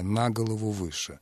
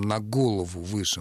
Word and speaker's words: на голову выше: то предлог должен забирать ударение на себя, на голову выше. А ударение на существительном, на голову выше, может даже вызвать совершенно нежелательные ассоциации, поскольку в современной такой непринужденной обыденной речи есть еще такое выражение «больной на всю голову».